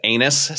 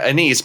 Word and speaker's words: anise: Thing is, though, like you might use and depending on where you're from, you might pronounce it anise seed. or as we say in America, anise, because we don't like saying anus if we can avoid it.